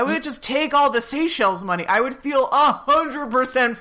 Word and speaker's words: Seychelles: That place, what was it, in the Cayman Islands or the Seychelles? Is that how you pronounce that? – Yes. – Seychelles? – Yes.